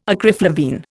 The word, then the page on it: acriflavine: Indian